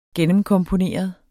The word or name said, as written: gennemkomponeret